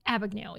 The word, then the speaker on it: Abagnale: Is that how you say it? Abagnale? – Frank Abagnale.